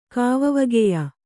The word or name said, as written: ಕಾವವಗೆಯ